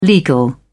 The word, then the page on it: legal: British English